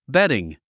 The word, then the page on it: bedding: bédiŋ; ベディング